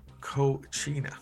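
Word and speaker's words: Cocuina: Manikin Cochina, pronounced Cochina. Gas field.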